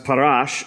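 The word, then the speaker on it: parash (פרש): The Pharisees was a religious sect, and the word Pharisee comes from a Hebrew word that is pronounced parash, and it means separate.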